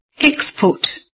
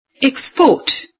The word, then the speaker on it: export: Put the stress on the first syllable so that you'd pronounce the words as nouns. – 1. Export.